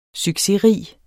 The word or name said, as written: succesrig